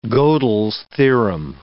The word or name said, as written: Godel's theorem